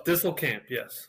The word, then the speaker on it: Disselkamp: Is that how you say it? Disselkamp? – Close. Disselkamp, yes.